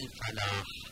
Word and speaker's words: الفلاح: قد کنید اینجا لام نزد ف قمری هستش و ادغام نمیشه، نمیشه بگم حي على الفلاح. و عنایت این لام رو تلفظ کنید حی علی الفلاح.